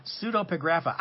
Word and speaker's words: Pseudepigrapha: Some of them were written in the intertestamental period and called Old Testament pseudepigrapha, or false writings. False writings. Pseudepigrapha.